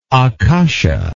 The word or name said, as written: akasha